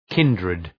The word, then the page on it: kindred: ‘kındrıd